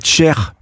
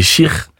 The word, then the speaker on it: Cheikh: On dit Mohamed Cheikh ou Mohamed Cher ? Parce que le KH se prononce, on dit Khaled quand c'est en arabe. – C'est exactement l'exemple que je prends en plus habituellement, donc c'est vrai que c'est Cheikh.